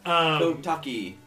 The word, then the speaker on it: Kotake: Kotake. – I pronounced the E at the end for him.